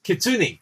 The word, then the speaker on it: kitsune: I want to say kuni. That's not right, is it? Hold on. What is the fox spirit? – Kitsune. – Kitsune. Yeah, kitsune. Yeah.